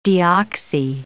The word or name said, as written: deoxy